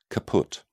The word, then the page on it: caput: British English